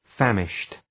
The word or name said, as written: famished